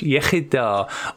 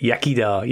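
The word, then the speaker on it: Iechyd da: That's Welsh, Yechida, or as a lot of people pronounce it, Yakida.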